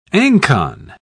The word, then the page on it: ancon: British English